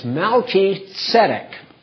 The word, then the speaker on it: Melchizedek: It's Melchizedek. Melchi means king in Hebrew, and Tzedek means righteousness. And Melchizedek, it's not Zedek. It's like you had a T-S. Tzedek. Melchizedek. And the accent is on the first and third syllables.